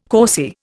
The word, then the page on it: causey: Indian